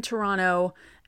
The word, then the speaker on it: Toronto: So yes, thank goodness every Yankee is in Toronto, and I'm saying it like a native, they don't pronounce the second T.